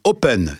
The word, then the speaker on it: Ho2pen: 10 postes à pourvoir à Rennes chez Open, ça s'écrit H-O-2-P-E-N.